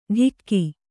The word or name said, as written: ಢಿಕ್ಕಿ